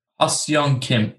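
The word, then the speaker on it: Ha-seong Kim: We're going to butcher his name, I know, but I think that's it, right? Ha Sung Kim? – Ha Sung Kim, yes.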